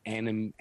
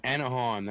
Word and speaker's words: Anaheim: The Anaheim, as in Disneyland. – When I read the article. – Yeah, as in Disneyland, Anaheim, California. – Anaheim, that's it.